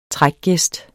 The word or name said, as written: trækgæst